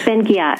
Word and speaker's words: Ben-Ghiat: And before we start again, Ruth, am I pronouncing your last name right? uh, ben Ghiat. Ghiat, Ben Ghiat.